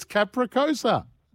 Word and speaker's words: capricciosa: Capricciosa. – I don't trust you people in Coolum. – It's not. It's capricciosa. You look at the spelling of it. – It's capricosa.